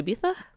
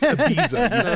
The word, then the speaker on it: Ibiza: Did you pop over to Ibiza? – No, I did not. Because that's stupid and a dumb way to pronounce it. It's Ibiza, you fucking Ibiza.